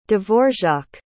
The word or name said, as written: Dvorak